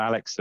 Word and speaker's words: Alex: Now I've remembered that both of our names are Alex and rather than Alec.